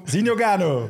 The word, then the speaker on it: Zinho Gano: Welkom, Zinogano. Dankjewel, dankjewel. Ine, was dat juist uitgesproken? Ja, kan ermee door.